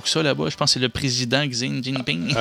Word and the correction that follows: Xi Jinping: Attends un peu, fais attention comment tu prononces son nom. – J'avoue que je n'ai pas l'accent. – Son nom, c'est Xi Jinping.